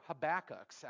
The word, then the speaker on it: Habakkuk: And according to YouTube, it was Habakkuk. So today we're going with Habakkuk.